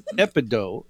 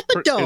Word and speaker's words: epidote: It's called Epido. It's Epido. – And it's spelled E-P-I-D-O-T-E.